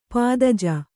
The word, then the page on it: ಪದಾಜಿ: padāji